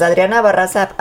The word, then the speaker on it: Adriana Barraza: Pues Adriana Barraza apareció ya recuperada del cáncer de mama, ¿lo dije bien? – Sí.